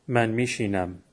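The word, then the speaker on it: می‌نشینم: To sit, نشستن, من می نشینم. Pronounced من میشینم.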